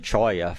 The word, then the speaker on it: cholla: People down here call it cholla from the Spanish pronunciation.